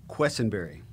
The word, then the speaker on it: Quesinberry: Quesinberry.